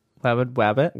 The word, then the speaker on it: Wabid Wabbit: Wabid Wabbit. – Wabid Wabbit. Pronounced like that because it's B's instead of T's.